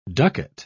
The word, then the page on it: ducat: British English